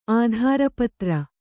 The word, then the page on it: ಆಧಾರಪತ್ರ: ādhāra patra